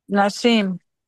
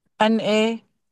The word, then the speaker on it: Nassim: Nassim. – I can't even pronounce that name. – NASSIM. – MASSIN. Okay, Father, we just. – N as Nancy. – Huh? – N as Nancy. NASSIM.